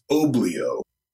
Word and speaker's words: Obleyo: It's Oblio.